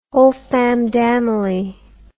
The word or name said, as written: wholefamndamily